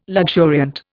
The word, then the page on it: luxuriant: Indian